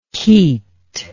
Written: keat

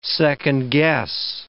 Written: second-guess

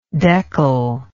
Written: deckel

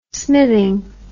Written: smithing

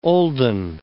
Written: olden

